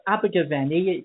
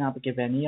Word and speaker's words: Abergavenny: It's called Abergavenny. It's quite difficult to pronounce. I was born in Abergavenny.